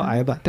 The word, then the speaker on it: 呆板: 你记不记得上学的时候，呆板它其实发音叫矮板。